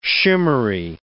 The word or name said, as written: shimmery